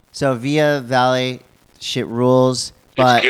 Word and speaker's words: Via Vale: It's V-I-A-V-A-L-E.